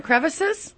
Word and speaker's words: crevasses: It's not crevices? – Well, it depends on where you're from. – Okay. – Because our producer, Frosty, he says it can go be pronounced either way.